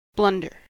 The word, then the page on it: blunder: American English